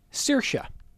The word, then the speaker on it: Saoirse: Again, the Irish immigrant, the name S-A-O-I-R-S-E, and the pronunciation is Saoirse.